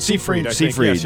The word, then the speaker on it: Seyfried: Amanda Seyfried, how do you say Seyfried? – Seyfried. Yes, yeah.